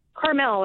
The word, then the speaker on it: Carmel: Carmel, like the town.